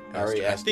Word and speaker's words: Ari Aster: And Ari Arister, uh, am I pronouncing his name correctly? I, I think Ari, I think Ari, it was Aster. Aster. Ari. – Ari Aster. Aster. yeah.